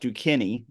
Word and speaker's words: Duquesne: Duquesne, the Duquesne Dukes.